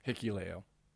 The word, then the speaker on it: Hikuleo: Hikuleo.